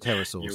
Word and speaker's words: pterosaurs: Well, I do pronounce it pterosaurs, because that is the correct pronunciation.